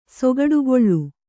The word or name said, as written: ಸೊಗಡುಗೊಳ್ಳು